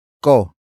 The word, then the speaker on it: ko: The next word is ko. Ko. Ko. Ko is spelled as K-O.